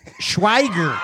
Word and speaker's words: Schweiger: Schweiger. – Yeah. – Pammon. – I tried to spell it phonetically. – Eric Schweiger. – There you go.